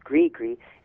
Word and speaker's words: gris-gris: It's spelled G-R-I-S, G-R-I-S, gri-gri.